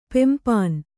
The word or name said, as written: ಪೆಂಪಾನ್